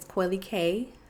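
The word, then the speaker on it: Coily Kaye: So on Instagram, my name is Coily K, C-O-I-L-Y-K-A-Y-E.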